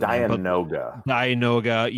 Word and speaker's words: Dianoga: There's another, like, I want to get the Diogna. I don't know how to pronounce it. – Dianoga.